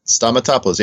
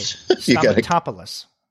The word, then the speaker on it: Stamatopoulos: Stamatopoulos. Is that how you pronounce it? – Stamatopoulos, yeah. The accent's always on the third syllable from the end. – Okay. Stamatopoulos.